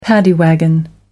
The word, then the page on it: paddy wagon: American English